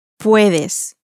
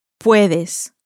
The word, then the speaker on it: puedes: Puedes. Puedes. – Now, this can, this you informal can, is spelt P-U-E-D-E-S.